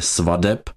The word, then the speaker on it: svateb: tedy vyslovuje se svadeb a ne svateb.